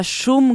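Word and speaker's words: Шум: Si pronuncia Shum con la U, anche se mi sembra che lei dica Shum, una roba del genere.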